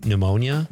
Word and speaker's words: pneumonia: John in Madison says, words with silent letters bug me too. Pneumonia? Dumb?